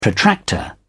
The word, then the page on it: protractor: British English